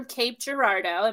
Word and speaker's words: Cape Girardeau: She is from Cape Girardeau.